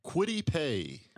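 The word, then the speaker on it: Kwity Paye: Quiddy Pay.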